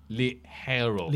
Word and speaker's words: ligero: Liguero, meaning lightweight, is pronounced more like Ligero. – Ligero. – Ligero in Spanish.